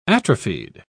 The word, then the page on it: atrophied: British English